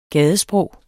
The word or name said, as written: gadesprog